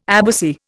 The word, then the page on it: abbacy: Indian